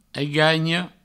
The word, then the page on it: aiganha: eigagno